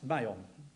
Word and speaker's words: Maon: Look at your marks above it, and that's how it's pronounced, Maon.